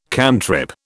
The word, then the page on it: cantrip: British English